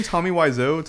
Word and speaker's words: Wiseau: Tommy Wiseau.